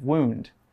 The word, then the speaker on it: wound: We pronounce the OU in wound with an OO sound. We don't say wound. Wound is also a word, but it's the past tense of another verb which means to coil something tightly around something else.